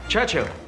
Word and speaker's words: Churchill: Churchill, that's how you say it, all together like that. I'd heard church and hill, but couldn't find either.